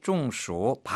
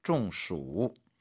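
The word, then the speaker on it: 中暑: 중暑. 발음 유의하십시오. 이때 가운데 중자는 쫑, 가운데라는 뜻의 일성이 아니라 쫑, 뭔가 적중되다, 뭐가 맞다라는 말로 해서 이제 더위를 맞았다, 더위를 먹다, 중수.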